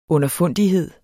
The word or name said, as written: underfundighed